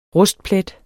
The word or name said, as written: rustplet